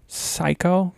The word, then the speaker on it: psycho: Psycho. Obviously, the P is silent.